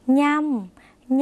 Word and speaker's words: ย่ำ: ย่ำย่ำไม่ใช่ยำนะคะเพราะฉะนั้นใช้เพยัญชนะตัวนี้ค่ะออกเสียงเนื้อเนื้อเป็นเสียงขึ้นจมูกเหมือนในภาษาอีสานหรือในภาษาภาคเหนือนะคะ